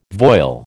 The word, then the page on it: voile: American English